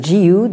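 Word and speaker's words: jiyu (自由): Et donc, dans cette traduction, on voit que le mot « liberté » est rendu non plus phonétiquement, mais sémantiquement, en empruntant l'expression chinoise « ziyo », qui se prononce « jiyu », donc en japonais.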